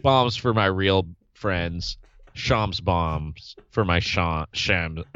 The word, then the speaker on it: Shams: Shams. Shams bomb. Sorry, yeah. shams. It rhymes with bombs.